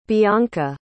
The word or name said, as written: bianca